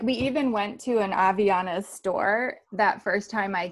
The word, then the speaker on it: Havaianas: Havaianas. – No, you're not going to pronounce it like an R. You're going to pronounce it like a silent H. Havaianas.